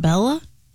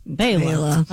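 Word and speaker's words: Béla: Born in 1958, the great banjo player, Mr. Fleck, first name B-E-L-A. – B-E-L-A. It's not Bella? – It's Bela. – Bela.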